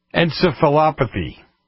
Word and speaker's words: encephalopathy: After his death, Dwersen was found to have had chronic traumatic, I can't even pronounce the next name, encephalopathy. How'd I do? Close? – No, you hit it right.